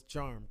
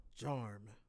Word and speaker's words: Jarm: Well, it's Jarm Carlos Ramirez. – John Carlos. – No, Jarm Carlos. – Jarm. – Yeah, Jarm. – Yeah. Like, spell that. – J-A-R-M, as in Mary.